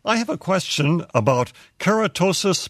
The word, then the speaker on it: keratosis: Keratosis, yeah.